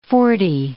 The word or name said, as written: forty